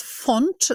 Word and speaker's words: Phont: And what about the final company here today is Font, and that's font as in typeface, but you spell it Phont.